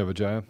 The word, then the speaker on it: Vijaya: Vijaya? – Mm-hmm. Yeah, Vijaya.